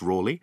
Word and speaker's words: Raleigh: I'm going to talk about Walter Raleigh, and it's, yep, pronounced Raleigh, and the myth of him laying down his cloak for Elizabeth I in his puddle.